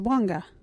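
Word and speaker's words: Wangga: Our local Wajiri word is Wanga.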